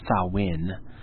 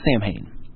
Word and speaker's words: Samhain: And now I realize it's pronounced Samhain, but we called it Samhain.